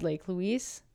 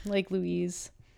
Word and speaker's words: Lake Louise: which is just beside Lake Louise and, or Louise, sorry, I always say that wrong. Lake Louise.